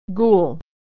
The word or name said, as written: ghoul